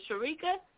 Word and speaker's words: Shereka: Is it Sharika? – Yes.